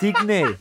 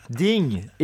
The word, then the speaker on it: Digne: It's not Digne. – What is it? – Ding. It's not. There's no accent on the E.